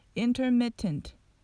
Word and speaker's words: intermittent: もちろんこのように intermittent と発音するのも間違いではないんですけれどもアメリカの英語の癖のようなもので mittent の tt の部分がうんと飛んでしまいますこれは glottal stop と言われる